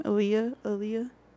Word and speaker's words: Aaliyah: How do you say her name? Aaliyah? – Her? Yes.